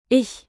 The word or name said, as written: ich